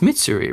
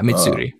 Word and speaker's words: Mitsuri: It's Mitsuri, right? I'm not saying that wrong. Yeah, Mitsuri.